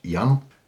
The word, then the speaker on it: Jan: Oh, it's actually Dutch, Jan, but Jan. It's okay. – Okay, Jan. – Jan is also okay.